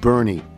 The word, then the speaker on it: Boerne: B O E R N E is pronounced Bernie.